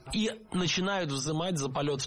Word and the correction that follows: взимать: Здесь с ударениями было все в порядке, но меня смутило слово «взимать», потому что так говорить не рекомендуется. Правильно, «взимать». С гласной «и» в первом слоге мы и пишем, и произносим это слово «взимать».